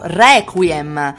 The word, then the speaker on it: Requiem: Requiem. È aperto, la E aperta sulla prima E.